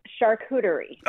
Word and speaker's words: charcuterie: So as my six-year-old will happily school you, it is charcuterie. – Oh, okay, so there is a soft char, okay, at the beginning, and then it's a cooterie? – Yes. – Charcuterie.